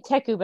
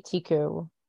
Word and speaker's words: Teku: Tiku, is that it? – Yeah, I say Teku, but I think you can say Tiku.